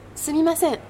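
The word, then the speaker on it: Sumimasen (すみません): On dit bien Sumimasen? – Sumimasen, oui. Tu le prononces très bien.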